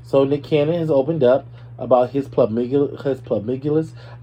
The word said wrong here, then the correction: polyamorous: I can never pronounce this word. It's polyamorous. We're going to do polyamorous.